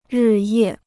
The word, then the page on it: 日夜: rì yè